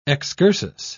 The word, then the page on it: excursus: British English